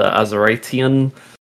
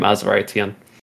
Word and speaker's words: Azuritean: And I also stream full-time on Twitch under Azuritean. Don't try and pronounce that, just say Azurite or Azu, that's fine. And yeah, most links I can be found under the name Azuritean.